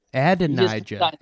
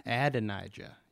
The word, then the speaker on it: Adonijah: Adonijah. – Adonijah.